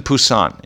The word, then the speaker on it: Busan: I was in Busan in Korea. Busan. It was a P, now it's a B, but the pronunciation is somewhere in between.